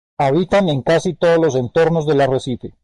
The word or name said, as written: arrecife